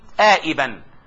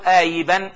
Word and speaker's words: آئبا: إذن موضع الشاذ في هذا البيت آئبا، حيث جاء خبر كاد آئبا أو آيبا اسما مفردا، وهذا شاذ نادر.